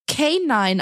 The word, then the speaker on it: K9: Kanine wird K9 ausgesprochen und deswegen steht auf diesen Hundegeschirren K9, weil das quasi die Übertragung, ja. also die, die ein geckige, geckige Schreibweise von K9 ist.